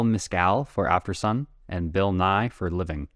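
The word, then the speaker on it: Nighy: It's spelled differently. It's N-I-G-H-Y.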